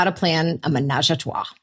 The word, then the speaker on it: ménage à trois: Oh, and it's called How to Plan a Ménage à Trois.